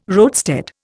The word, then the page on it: roadstead: Indian